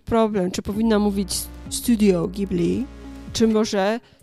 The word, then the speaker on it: Ghibli: E, I pamiętam, że byłam w totalnym szoku, e, bo to GH, wydawało mi się, że to jest może jakiś amerykański zapis niemego G, że to jest naprawdę Ghibli, E, bo przecież jest taka zgłoska gi w japońskim. E, I pamiętam, że doznałam szoku, że to, że to się naprawdę wymawia Ghibli,